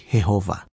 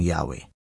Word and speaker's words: Jehovah: Sa bawat wika, iba-iba ang bigkas sa pangalang iyan. Sa Tagalog, ang karaniwang bigkas ay Jehovah. Ang iba naman ay binibigkas itong Yahweh.